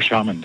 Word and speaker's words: shaman: And yeah, oh, shaman, that's how it's pronounced.